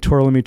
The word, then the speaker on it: Tourlami: did I say that right? – No. – Tourlami.